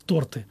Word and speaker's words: торты: То есть, например, тоже у нас была такая не влезаешь в шорты, прекрати есть торты. То есть вот слово «торты» запоминается хорошо, потому что ударение правильно ставится. Таким образом, какие-то веселые всякие задачки всякие выставляли в Facebook.